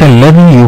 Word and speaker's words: الذي: الذال، حرف الذال حرف مظلوم معنا، خاصة أننا في لغتنا العامية إما ننطقه دالا أو زايا، يعني الذال غير موجود عندنا في اللغة العامية المصرية. – نعم. – أما بنكون حريصين إن إحنا ننطقه يخرج صوته شبيه بالذال، بنلاقينا بننطقه زين، فالناس تقول الذي، وهذا خطأ.